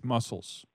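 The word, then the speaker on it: Mussels: It is P-A-T-C-H. – All right. And your last name? – It's Muscles. Patch Muscles. – Interesting. – Well, it's M-U-S-S-E-L-S.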